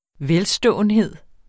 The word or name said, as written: velståenhed